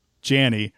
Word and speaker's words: Janney: this is how I would pronounce it because the E's there, so it looks like J-A-N, and then I'm like, oh, there's two, okay, Janney, all right, so she was good, but I remember us watching that and going, like, man, she needed her own episode.